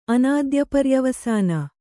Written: ಅನಾದ್ಯಪರ್ಯವಸಾನ

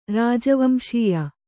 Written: ರಾಜವಂಶೀಯ

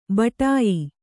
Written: ಬಡಾಯಿ

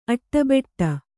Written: ಅಟ್ಟಬೆಟ್ಟ

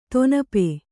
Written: ದಿನಪ

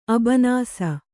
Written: ಅಬನಾಸಿ

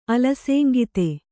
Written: ಅಲಸೇಂಗಿತೆ

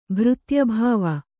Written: ಭೃತ್ಯಭಾವ

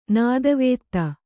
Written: ನೆತ್ತಿವತ್ತು

